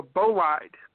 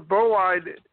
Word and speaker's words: bolide: That's actually called a bolide. It's spelled B-O-L-I-D-E.